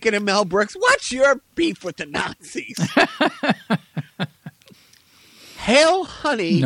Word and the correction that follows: Heil: You mispronounced that word. Try it again. – Heil, honey.